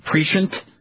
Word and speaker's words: prescient: That's sort of, what's the word, prescient? – Prescient? – Yeah. Is that how you say it? – Yeah.